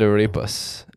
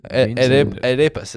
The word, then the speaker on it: arepas: My number three has got to be, that rhymed, beans and arepas. Arepas is how you say it.